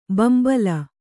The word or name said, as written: ಬಿಂಬಲಿ